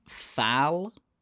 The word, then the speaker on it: Thal: Well, he was born in, pronunciation incoming, Thal, T-H-A-L. – Thal, T-H-A-L, yeah.